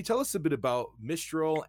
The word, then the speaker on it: Mistral: The first thing to, know is it's pronounced Mistral, and uh, it's a subtle nuance, but the name comes from a wind in Europe that comes from the north and uh, and packs a wallop.